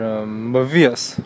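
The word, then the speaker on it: Mevius: 应该叫，嗯用这个周数标准发音应该是 Mavius，类似这个，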